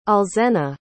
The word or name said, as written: alzena